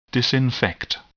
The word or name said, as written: disinfect